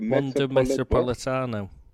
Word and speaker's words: Metropolitano: So that's first off, we've got to go to the one, the Metropolitana, where we won it last year. – Oh, that's right. Yeah, I've never been able to pronounce that. Say that again. – The Wanda Metropolitano.